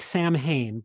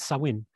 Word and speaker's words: Samhain: It was a pagan festival called Samhain, which is spelled with an M, but it's silent because it's Irish and they just hate me and don't want me to be able to speak their language. – And I've seen that word before and had no idea that's how you pronounce it. – Yeah, it looks like Samhain, but it's of course not. It's Samhain.